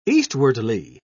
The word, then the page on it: eastwardly: British English